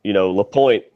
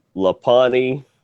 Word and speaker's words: LaPointe: LaPointe, it seems fairly easy, but I've gotten everything from LaPani, LaPonte, Lapanite.